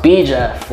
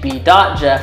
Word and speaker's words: B. Jeff: Dot Jeff, but it was supposed to be pronounced B. Jeff, like literally B. Period Jeff. But everyone just kept saying B. Dot Jeff.